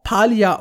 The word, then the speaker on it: Palia: Und dass die Entwickler übrigens auch Palia ausgesprochen haben. Ich bin sehr, sehr beruhigt, ja. In dieser Lautsprache sah es ja irgendwie strange aus, aber anscheinend hat sich das so durchgesetzt, dass es doch ordentlich ausgesprochen wird.